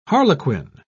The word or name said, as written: harlequin